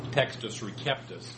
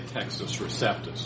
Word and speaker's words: Textus Receptus: In classical Latin, that C would be pronounced like a K, so Textus Receptus. But it's usually anglicized to say Textus Receptus.